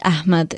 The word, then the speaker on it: Ahmed: So Afroz is spelled A-F-R-O-S-E. Fatima is F-A-T-I-M-A. And Ahmed is A-H-M-E-D.